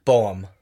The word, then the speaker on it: Boehm: Boehm.